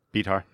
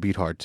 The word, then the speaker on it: Beathard: But that's why every time I gotta say his name, I gotta like, wait, it's not Beat Hard, it's Beathard.